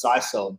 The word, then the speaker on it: sisal: And I'm like, so some guy comes up in my comments, he's like, you know it's pronounced sisal, don't you?